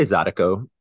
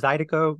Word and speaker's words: zydeco: I say Zydeco, you know, it probably is Zydeco. That's just my accent, you know, either is acceptable.